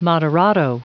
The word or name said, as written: moderato